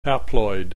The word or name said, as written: haploid